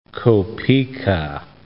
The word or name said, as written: kopiyka